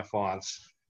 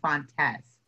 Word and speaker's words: Fontes: Font is the correct way to pronounce it. I pronounce it the Americanized way, Fontes.